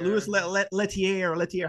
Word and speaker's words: Louis Leterrier: Louis Leterrier, how you say it.